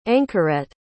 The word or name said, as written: anchoret